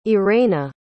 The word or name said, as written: irena